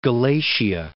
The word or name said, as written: Galatia